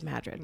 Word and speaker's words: Madrid: And Madrid is named after, but not pronounced like Madrid, Spain. – Yes, please don't call it Madrid when you are in Madrid. – Yes. It is Madrid. Madrid. Yep.